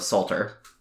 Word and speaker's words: Psalter: And in the early days, they would pray the entire book of Psalms, which is also called the Psalter. – Okay. – This is all spelled with a P at the beginning of it. – Yeah. – Silent P.